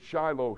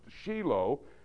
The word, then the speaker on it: Shiloh: You pronounce it Shiloh here. In Hebrew, it's pronounced Shiloh.